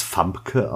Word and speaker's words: Famke: Gespielt von, spricht man das Famke aus? Soweit ich weiß, ja. Ich glaube, sie ist Holländerin.